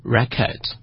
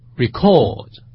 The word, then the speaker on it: record: 如果它的意思是名词，名词的话我们读成 record， record。如果它是动词记录的话就叫做 record， record。大家可以发现这个重音的不同，一个是名词，一个是动词。